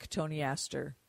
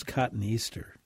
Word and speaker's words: cotoneaster: You can do that with cotoneaster. And that should rejuvenate that hedge pretty nicely. – Before I learned the pronunciation from you and your colleagues, I always thought it was cotoneaster.